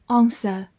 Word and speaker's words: answer: Answer, A N S W E R, answer, A N 发 on，S 发 s，W 在这里不发音，E R 发 a，answer，answer，美式发音字母 A 发大口的 a，末尾的 R 有一个卷舌，answer 也是可以的，它是一个名词或者动词，表示答复、回答。